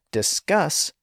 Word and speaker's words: discus: And that's because discus puts the stress on the first syllable, so a single S is fine at the end. But discuss puts the stress on the final syllable, and since it's pronounced strong, to use Mulcaster's term, the word needs to have double S's at the end.